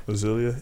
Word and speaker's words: Azalea: Azalea.